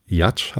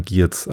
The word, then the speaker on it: Giertz: Ja, und aber mein Lieblingsvortrag, beziehungsweise mein Lieblingsgast, war Simone Jatsch, habe ich jetzt gelernt, spricht man sie aus. Ich dachte immer Giertz, aber sie wird wohl Jatsch ausgesprochen.